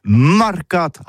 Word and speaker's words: mercats: Mais en gascogne toulousain, c'est prononcé les marcates avec un A.